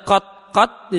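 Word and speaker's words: qat qat (قط): Ya, untuk cara baca qat qat di sini ada maka dijelaskan di Asmuni Sarah Alfiyah, bisa dibaca dengan sukun ta. Ta-nya disukun, qat qat.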